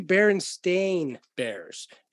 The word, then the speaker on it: Berenstain: S-T-E-I-N? Steen? Yeah, that's what most people think. It was actually Berenstain Bears, S-T-A-I-N. – And Stain. – Yeah, Berenstain, they were pronounced Berenstain Bears, but it was S-T-A-I-N.